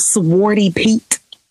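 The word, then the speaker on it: Zwarte Piet: Have you all ever heard of Swarty Pete? Swarty Pete? No? – Spell it. – Z-W-A-R-T-E P-I-E-T-S.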